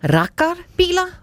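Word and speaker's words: raggarbilar: vil forbyde svenskernes elskede rakkerbiler. Udtaler det rigtigt, Ole Ryborg? – Ja, det er rigtigt. Rakkerbiler.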